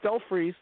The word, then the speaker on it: Stelfreeze: and Brian Stelfly's on the panel. Am I pronouncing his name correctly? – It's close enough. I always use Stelfreeze, so.